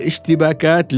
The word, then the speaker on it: اشتباكات: ينطق كلمة اشتباكات بطريقة صحيحة ولكنه مصر أنها اشتكابات يا ابن الحلال اشتباكات لا اشتكابات وعندما نطقها أول يوم بطريقة صحيحة احتفلت معه فهو عندما شاهد آه، حجم فرحتي ب...